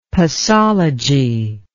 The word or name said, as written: posology